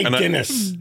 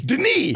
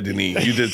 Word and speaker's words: Denis: I didn't say Dennis. – He did not. He did not say his name? – That. – What's his name? – Denis. – Oh, yeah, you did. You did say Denis.